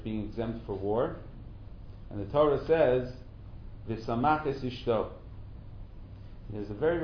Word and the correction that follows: V'simach: It says the first year he's supposed to be with his wife, excuse me, I pronounced the pasuk incorrectly, V'simach es ishto, asher lakach. In fact, the way I read, the way I vowelized the pasuk is exactly what Rashi tells us not to do.